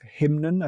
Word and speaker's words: Hymnen: And then in the next chapter, we're in a different place again, and we're looking at Karlheinz Stockhausen's piece Hymnen, I think it's pronounced. – That's which right, is, yes.